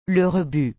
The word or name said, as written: le rebut